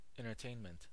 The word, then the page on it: entertainment: American English; [ˌɛn.təˈteɪn.mənt]; /ɛn.tɝˈteɪn.mənt/